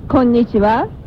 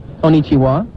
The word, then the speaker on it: Konnichiwa: Oh, your pronunciation is not a good. Konnichiwa. – Konnichiwa. – Oh, that's better.